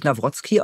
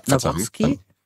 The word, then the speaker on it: Nawrocki: Also ich glaube, der spricht sich Nawrocki aus. – Ja, Nawrocki. Meine ich so gehört zu haben.